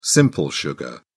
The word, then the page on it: simple sugar: British English